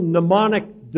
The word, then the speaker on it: mnemonic: And I'm going to give you a little mnemonic device, M-N-E-M-O-N-I-C, mnemonic. It's an odd way to spell it, isn't it? Do you ever wonder why the word phonetic is not spelled phonetically?